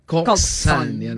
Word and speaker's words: coxswain: Ang pagbasa po ng coxswain, pero pag pronounce po nito ay coxswain.